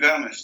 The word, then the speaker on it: Gámez: Jesús Gámez.